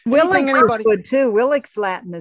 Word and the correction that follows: Wheelock's: I think it's W-H-E-L-O-C-K-S. I'll have to look that up. – Oh, Wheelock's.